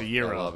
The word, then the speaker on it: gyro: Akiro. – It's a gyro.